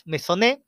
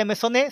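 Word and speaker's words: Meissonier: Dos cuadros del siglo XIX, los puedes googlear, Espolarium de Juan Luna Novicio y La campaña de Francia de Ernest Messonet, se escribe Meissonner, tuvieron mucho reconocimiento en su época. Por la obra de Maisonet se pagó el precio más alto por un artista vivo, 850 mil francos.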